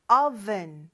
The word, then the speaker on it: oven: Oven. 이 발음이에요. 영어 발음.